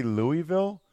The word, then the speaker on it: Louisville: if you're from Louisville, you say it like that. Everywhere else, like in the Northeast, we say Louisville. But I've been around people that are from there, and I've been there, and they say it's pronounced Louisville. So I feel like I should say Louisville.